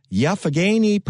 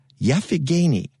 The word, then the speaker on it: Youghiogheny: And you want to get your dirty little hands on their heirloom garlic variety, the Rock'n'Bowl Garlic, in a lovely Yaphagany purple. And that's, she told me that, you know how you spell that? Y-O-U-G-H-I-O-G-H-E-N-Y. Yaphagany purple.